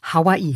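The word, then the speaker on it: Hawaii (Hawaiʻi): Hawaii korrekt nativ geschrieben und nativ ausgesprochen ist übrigens Jetzt kommt das besagte Okina, Hawaii.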